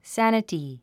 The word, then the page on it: sanity: sǽnəti; サァナティ